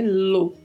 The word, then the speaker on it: lo: A pronúncia é lo, como se tivesse um acento circunflexo na letra O mas,